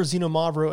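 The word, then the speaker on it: Xinomavro: And by the way, when you're looking for Xenomavro in the wine shop, just know it's not spelled with a Z. It's X-I-N-O-M-A-V-R-O, Xenomavro, which means acid black, because it has a very high acidity.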